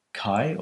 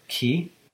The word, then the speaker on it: chi: Is it's built on the Greek letter chi or chi. It's probably the more correct pronunciation.